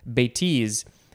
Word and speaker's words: bêtise: I think maybe I said bêtise before, but the emphasis is on the second syllable, bêtise, uh, because that one describes me very well.